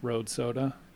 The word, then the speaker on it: Rohde Soda: It's the channel name is pronounced Rohde Soda. So R-O-H-D-E period soda, S-O-D-A.